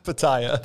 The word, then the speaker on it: pitaya: Do you remember how to say it? – That Pitaya. – Pitaya. – Pitaya. I was not close, but the Pitaya Berry Nectar.